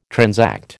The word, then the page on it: transact: American English